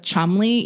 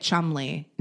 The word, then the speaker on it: Cholmondeley: And it's actually pronounced the Marchioness of Cholmondeley, even though it looks way no crazier. Way. That's how you say it, Cholmondeley. – No way. – Yes. And it's written like Shamala Dalalina. Like, it has like 100 syllables, but it's pronounced Cholmondeley.